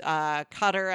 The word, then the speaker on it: Qatar: Yeah, so yeah, it's banned in Saudi Arabia, Egypt, Qatar, I think, if that's how you pronounce it. – Yep.